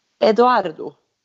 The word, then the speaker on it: Edoardo: Hon ska ju då i maj stå brud och gifta sig med sin italienska pojkvän Edoardo.